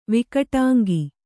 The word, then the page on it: ವಿಕಟಾಂಗಿ: Kannada